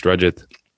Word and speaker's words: Rajit: Obviously my full name's Rajit, and then the T, it's difficult to explain to people. The T sort of sounds like a TH, just a short A and stuff like that.